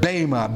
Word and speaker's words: bema: So we've noted the other passage is over in 2 Corinthians 5:10, and this of course speaks of the word bema, B-E-M-A in the Greek, pronounced long A.